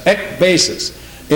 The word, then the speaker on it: ekbasis: I looked up that word end in the concordance, the Greek, and it says number 1545, ekbasis is the Greek word, if I'm pronouncing it right, E-K, and then B-A-S-I-S, ekbasis.